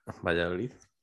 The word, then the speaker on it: Valladolid: Valladolid. – Look at that pronunciation. Say that again. – Valladolid. – Okay, I love that.